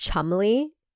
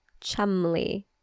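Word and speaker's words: Cholmondeley: Also, did you know that word is pronounced Chumley? – Can you say it one more time? – Chumley.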